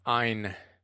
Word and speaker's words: Ayn: So Ayn Rand, and that's how you pronounce her name, Ayn.